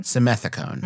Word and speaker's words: Simethicone: Simethicone.